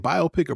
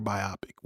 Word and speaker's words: biopic: It's not biopic. It's biopic. And anyone who says biopic can expect a warrant to be served to them at their doorstep by me. – Emphasis on the wrong syllable.